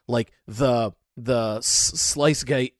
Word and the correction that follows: sluice gate: or Sluice Gate. I don't know how you pronounce that word. – Sluice Gate. – Sluice Gate.